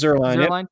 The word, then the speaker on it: Zierlein: He's Lance Zierlein Zierlein, is that how do you pronounce it? – Zierlein. Yep.